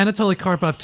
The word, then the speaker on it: Anatoly Karpov: Did I get the name right? – Yeah, Anatoly Karpov took the title in from 75.